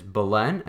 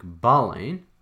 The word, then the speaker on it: Boleyn: They are King Henry VIII, his wife, Queen Catherine of Aragon, his sweetheart, Anne Boleyn, and yes, I know that the way to pronounce the name is Boleyn, and not as I said it last week, Boleyn, and the king's longtime confidant and minister, Thomas Wolsey.